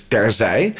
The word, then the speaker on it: Terzij: So the first word is Terzij. And that's pronounced as Terzij. But our American fans, you know, if they don't know how to say it, they'll be like Terzij or something, because IJ doesn't make any sense.